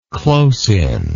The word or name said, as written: close-in